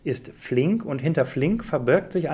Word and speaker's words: Flink: Und es geht um Flink. Habe ich das richtig ausgesprochen? – Du hast es perfekt richtig ausgesprochen.